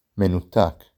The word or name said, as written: מנותק